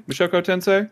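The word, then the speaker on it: Mushoku Tensei (無職転生): Mushoku? Tensei? Mushoku Tensei? – I believe it's Mushoku Tensei.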